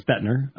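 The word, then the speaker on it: Boettner: So Augustine was kind of the first, I think it's Lorraine Bottner or Bettner, however you pronounce that, who was also a Calvinistic historian who admits that Augustine is the first to introduce these things. – Lorraine Bottner. Okay. It's Bettner. At least Dr. Wilson knew that.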